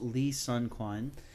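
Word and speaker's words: Lee Sun-kyun: We also lost Lee Sun Kwan. Am I pronouncing that correctly? – Yes, at least I believe so.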